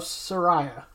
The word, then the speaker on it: Saraya: All right, so AEW did recently see the debut of Saraya.